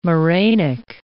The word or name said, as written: morainic